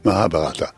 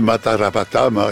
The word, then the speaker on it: Mahabharata: Je me permets de vous féliciter pour votre prononciation parce que vous avez bien dit Mahabharata. – Voilà, comme quoi ça m'arrive. Tout arrive. – Les gens disent Matarabata, etc.